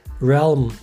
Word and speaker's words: realm: Burada antiparantez yeri gelmişken realm diye okunuyor. – Yazılışı real me şeklinde yazılıyor.